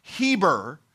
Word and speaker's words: Heber: And it has a rough breathing mark on it, and so we would pronounce it Heber, and it's where the word Hebrew actually came from.